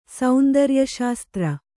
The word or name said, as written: ಸೌಂದರ್ಯಶಾಸ್ತ್ರ